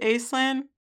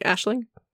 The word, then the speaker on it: Aislinn: Mm, is it Aislinn? – That's how the author pronounces it. But how is it supposed to be? Aisling? – I thought that was Aisling, right? A-I-S-L-I-N-N? – Yeah, that's the Gaelic.